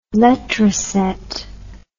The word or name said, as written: letraset